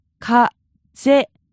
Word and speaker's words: 風邪: 風邪. 風邪. – Now, this sounds like the word for wind as well. – Yes, it's the same pronunciation.